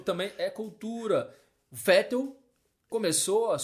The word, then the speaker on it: Vettel: Nem é assim que fala, né? – É, se a gente for falar no, no alemão mais correto, né? Seria Sebastian Vettel, né? Porque em alemão o V se pronuncia como F.